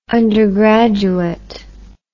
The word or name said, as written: undergraduette